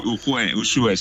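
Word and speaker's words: Ujué: Ujue, lo he dicho bien. Ujue, ¿no? Es Ujue, ¿no? – Sí, Ujue, sí, Ujue, Navarra, sí, está bien dicho.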